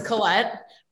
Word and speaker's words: Colette: My husband has always called me Colette, and it wasn't until my brother told him, that's not how you say your name. I'm just used to people pronouncing it wrong. So it's Colette, but you can pronounce it however you feel.